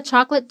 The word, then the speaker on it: chocolate: Em português, nós dizemos chocolate, com quatro sílabas. Mas no inglês a palavra soa como duas. Chocolate.